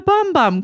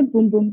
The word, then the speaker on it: Bum Bum: And thank you for pronouncing it correctly. We call it the Boom Boom Cream, the Brazilian Boom Boom Cream, because boom boom means butt in Portuguese.